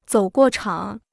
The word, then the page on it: 走过场: zǒu guò chǎng